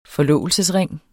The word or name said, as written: forlovelsesring